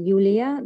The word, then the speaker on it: Yulia: So the name is pronounced in Russian, Yulia Dalgich, and I would like to know my powerful moments, please.